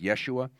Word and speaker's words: Yeshua: That's just the Greek version of the Hebrew name Joshua, which is actually pronounced Yeshua.